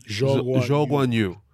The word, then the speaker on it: Zhou Guanyu: Zhou Guanyu. – And he's not French. – No. Chinese driver, rookie driver, Alfa Romeo driver, Valtteri Bottas' teammate. China versus the world, or Zhou Guanyu versus the world?